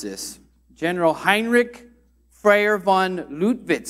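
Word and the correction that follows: Lüttwitz: Is that pretty good? Anyone have a better German accent? von Luttwitz, okay?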